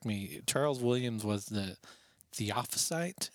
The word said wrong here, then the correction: theosophist: Is that how you say it, Adam? – Theosophist. – Theosophist, thank you.